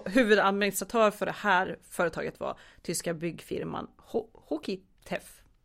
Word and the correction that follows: Hochtief: Hochtief. Hoch, ja tack.